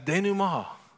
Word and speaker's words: denouement: It's spelled D-E-N-O-U-E-M-E-N-T. It's French. So I thought that was always a denouement. And my son said, "No, Dad, it's a denouement."